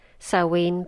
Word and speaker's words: Samhain: It's actually pronounced Samhain, but the spelling is Samhain.